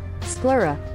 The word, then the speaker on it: sclera: Sclera.